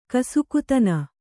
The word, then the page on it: ಕಸುಕುತನ: kasukutana